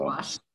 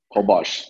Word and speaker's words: Holbox: So, Holbox. – Holbox. – Okay. – I can't pronounce that. For me, if I read it, it's Hallbox. The people say Hallbox.